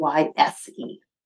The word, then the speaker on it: Royse: It's Royse.